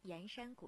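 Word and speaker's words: 铅山: 我们的脚步来到江西盐山古镇。说到盐山，其实盐字就是铅笔的这个铅字，在这里呢要读成盐。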